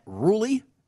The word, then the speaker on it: Rhule: Panthers coach Matt Rhule, as Kelly tells us it's pronounced, he announced that Sam Darnold will get the start this week.